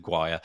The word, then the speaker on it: Guyer: I'm going to say Guire. Does anybody know David? – I think that's right. – Okay. – I could be okay. – wrong. – Yeah, David's surname is spelled, David's surname is spelled Guyer. So I'm going with Guyer.